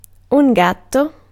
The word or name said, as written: chatte